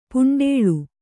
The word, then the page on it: ಪುಂಡೇಳ್: kannada; puṇḍēḷ